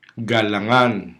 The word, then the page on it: galangan: Hiligaynon